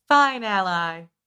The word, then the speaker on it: Fine-Ally: It is spelled. Finally, like F-I-N-E hyphen ally. Fine ally.